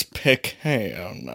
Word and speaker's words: pecan: you want to pronounce it, we would get into a debate on how to pronounce that word, because I still don't know the proper pronunciation. – Pecan. – It's pecan. – Pecan. – That's funny. The moment you said you were describing your beer, I wanted to dive into pecan or pecan.